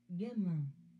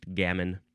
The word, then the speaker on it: gamin: Gamin. – Yep, that's a much more Frenchy way to pronounce that word. Gamin. And in English, it's just gamin.